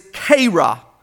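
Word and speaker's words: kera (χήρα): The Greek word for widow is kera. We would spell it C-H-E-R-A.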